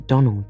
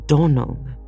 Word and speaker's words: Donnell: I'm sorry, faltered Anne, vainly trying to recollect any incident of the morning connected with the Donnell children. Clarice Almira told me that you pronounced our name Donnell. Now, Miss Shirley, the correct pronunciation of our name is Donnell, accent on the last syllable.